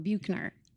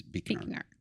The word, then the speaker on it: Buechner: I didn't realize that's how we say his name. I kept going Buchner. Yeah. The way it's spelled. It, yeah. So if I fall into that, you know, Yeah, it is. And, and so do I normally refer to him as Buchner, but the proper pronunciation, in my understanding, is Bikner.